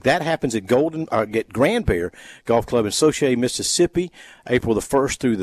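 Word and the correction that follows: Saucier: Am I saying that right, Bobby? – Soshiay? Grand Bear will be in Gulfport. – Well, they have it listed as Soshiay. So Socher? – Socher. – It's spelled one way and it's pronounced another.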